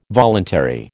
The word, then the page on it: voluntary: American English